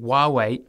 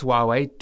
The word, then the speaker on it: Huawei: The British government will very soon decide whether to let Chinese technology giant Huawei into its 5G market. And the decision will influence whether the Australian government lets Huawei do the same here. – Huawei. H-U-A-W-E-I.